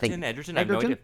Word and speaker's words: Egerton: Taron Egerton, is that his name? Is that how you say it? – Egerton.